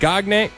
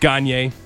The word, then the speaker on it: Gagne: Credit Union Phil, Eric Gagne. I mispronounced. I know it's pronounced Gagne.